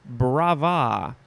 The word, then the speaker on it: brava: Moving on to brava. And I think you can enunciate either the first or the second syllable, however you so choose. Brava, brava, brava.